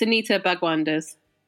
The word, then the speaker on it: Anita Bhagwandas: It's Anita Bhagwandas.